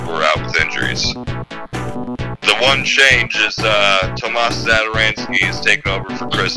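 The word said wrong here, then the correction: Tomáš Satoranský: Tomas Satoransky.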